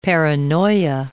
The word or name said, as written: paranoia